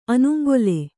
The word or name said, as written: ಅನುಂಗೊಲೆ